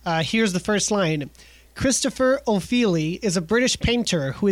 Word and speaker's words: Ofili: Well, I feel like you're not wrong because Ofili sounds Italian as opposed to British, but because it's spelled O-F-I-L-I.